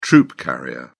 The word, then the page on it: troop carrier: British English